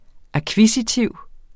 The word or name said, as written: akkvisitiv